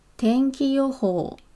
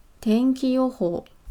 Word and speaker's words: 天気予報: 天气预报的日文呢，念作天気予報。天気予報。